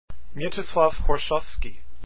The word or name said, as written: Horszowski